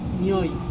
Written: nioi

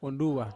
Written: wonduwa

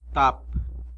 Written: tap

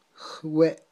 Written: jwe'

